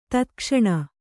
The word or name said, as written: ತತ್ಕ್ಷಣ